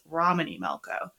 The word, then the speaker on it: Romany Malco: Like, we're recording in two sessions, and I accidentally pronounced his name incorrectly last time, so it's Romany Malco.